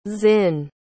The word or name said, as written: zin